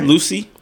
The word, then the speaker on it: Lucie: St. Lucia? – Lane Lucia. – How do you spell it? – L-U-C-I-E. – Okay. – All right. Lucy? – Lucy?